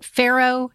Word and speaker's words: farro: I know we've tried quinoa and is it farro? Is that how you pronounce that? – Mm-hmm.